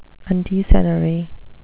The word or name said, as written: undecennary